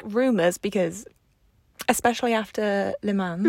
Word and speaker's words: Le Mans: I called it Le Mans to my parents because I was like, I know in French they ain't going to be pronouncing the S.